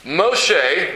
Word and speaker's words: Moshe: Moshe, that's his name in Hebrew, sounds like the word mashah, which means to draw out.